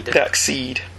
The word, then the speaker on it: Darkseid: Darkseid.